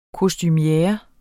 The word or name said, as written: kostumiere